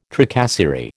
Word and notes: tracasserie: American English

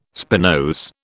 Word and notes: spinose: British English

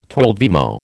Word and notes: twelvemo: American English

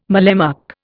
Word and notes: mallemuck: Indian

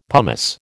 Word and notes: pumice: American English

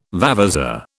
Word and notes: vavasour: British English

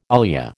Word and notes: ilia: American English

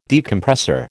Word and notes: Decompressor: American English

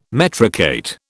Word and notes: metricate: British English

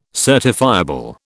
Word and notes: certifiable: British English

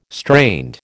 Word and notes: strained: American English

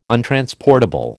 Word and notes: untransportable: American English

